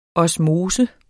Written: osmose

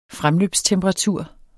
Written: fremløbstemperatur

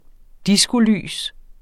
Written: diskolys

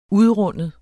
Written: UDRUNDEN

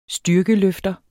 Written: styrkeløfter

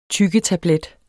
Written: TYGGETABLET

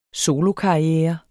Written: solokarriere

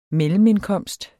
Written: mellemindkomst

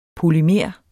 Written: polymer

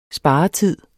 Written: sparetid

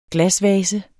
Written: glasvase

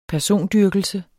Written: persondyrkelse

